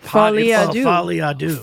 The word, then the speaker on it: Folie à Deux: It's called that Joker 2 movie is called like Folie à Deux. – That's exactly how you say it.